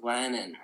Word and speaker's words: Glennon: Is it Glennon? Am I pronouncing it? – Glennon.